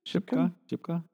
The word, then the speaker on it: Shipka: The actress's name is Kieran Shipkin. – Shipka? – I'm pronouncing that name very wrong.